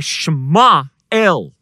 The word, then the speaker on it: Samuel: Samuel, we say it in English, Samuel, but in Hebrew, the way you pronounce Samuel's name is Shema El.